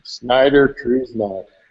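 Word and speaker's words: Schneider Kreuznach: Snyder Kreuznach.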